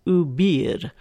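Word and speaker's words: obéir: So if you're speaking decent French, I don't even know if I can call this pronunciation decent French, it's oubir, and it's spelled O-B-E-I-R, and it's a spanking bench.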